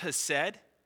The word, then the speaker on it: hesed: That word steadfast love is a Hebrew word that's pronounced hesed.